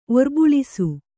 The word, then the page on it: ಒರ್ಬಳಸು: orbaḷasu